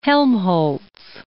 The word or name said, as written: Helmholtz